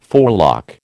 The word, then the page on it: forelock: American English